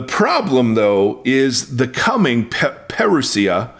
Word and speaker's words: parousia: Now, that word is a form of the word parousia or parousia, but I think it's parousia. It's how you pronounce it.